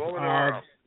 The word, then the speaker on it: Molinaro: Molinaro. – Molinaro. – Yeah, I mispronounced that. I've got a lot of grief for that one. – Oh, okay. Because I'm reading it like an Italian would read it the way it's spelled.